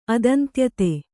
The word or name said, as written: ಅದಂತ್ಯತೆ